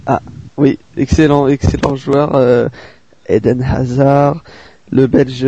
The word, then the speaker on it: Hazard: Je sais pas, je t'ai senti vouloir prononcer un peu Hazard comme ça. Oui, Eden Hazard. – C'est Dan qui m'a un peu motivé. Voilà, pour le prononcer à la juive. Non, je plaisante. Donc, parce que je me suis trompé, il me semble que j'ai prononcé un espèce de H avant le Eden.